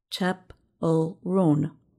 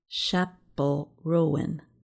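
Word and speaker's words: Chappell Roan: The list says her name is pronounced Chapel Roan, not Chapel Rowan.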